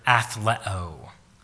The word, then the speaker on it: athleo: The Greek word is the word athleo. Athleo. Does that sound like an English word? It sounds like the word athlete.